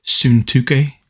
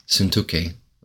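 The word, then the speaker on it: Syntyche: Has two upsilons in it, so it's pronounced Suntuke. – Suntuke.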